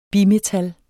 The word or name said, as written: bimetal